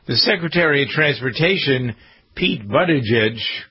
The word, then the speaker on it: Buttigieg: Boot, edge.